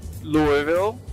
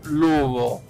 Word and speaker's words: Louisville: Se en inglés cualquier persona lo pronunciaría Louisville ¿ok? Pero así lo pronuncian la gente que no es de Louisville. La gente que es de Louisville lo pronuncian Lubo.